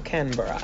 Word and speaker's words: Canberra: Canberra.